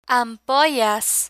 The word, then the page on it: ampollas: spanish